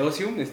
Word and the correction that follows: Osius: ¿Lo dije bien o...? Osius. Osius, perdón. Osius.